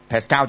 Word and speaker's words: Pascal: 再用 millibar 啦，咁佢就反而用一個單位咧就叫做 Pascal。 Pascal 咧其實係一個科學家嘅名嚟嘅，即係等於咧你哋就係好多以前咧，不過而家都冇乜人寫啦，係嘛？即係寫嗰啲 computer program 啊，咁你咪有一隻叫做一個 software，即係咪你寫個 programming language 咪叫做 Pascal 嘅， Pascal 咧其實就係嗰個名啦，Pascal，Pascal， Pascal 就係嗰個正式法文個發音啦。